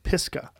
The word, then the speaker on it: Pisgah: Yeah, and I think that training with you also, when you took that dude running and you run Mount Pisgah, how do you say that? – Pisgah.